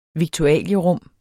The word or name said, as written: viktualierum